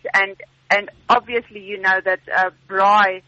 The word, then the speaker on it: braai: Is that you pronounce it bride? – Braai.